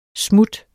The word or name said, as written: smut